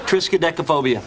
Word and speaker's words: Triskaidekaphobia: Triskaidekaphobia.